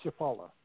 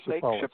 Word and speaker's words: Chapala: Lake Chappas. Is that right? How do you say that? – Lake Chapala. Lake Chapala.